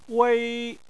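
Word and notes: wei: tone 1

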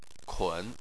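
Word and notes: kun: tone 3

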